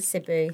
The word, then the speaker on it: Cebu: Cebu.